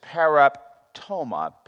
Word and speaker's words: paraptoma: But the second word is paraptoma, P-A-R-A-P-T-O-M-A, and that means to trespass, crossing the line. Those two are probably the two dominant words in the New Testament translated for sin. P-A-R-A-P-T-O-M-A, to trespass or cross the line. The next one is parabasis. P-A-R-A, basis, B-A-S-I-S.